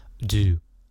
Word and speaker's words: do: 第二个单字呢就是 do do 做什么的意思，做嘛，d o 不要念成度度量衡的度或者是程度的度，它是有动态的 do。有像章鱼嘴，do，你看它的声音是呜呜呜的感觉，do do，然后念成 do，来跟我念一次，do do do。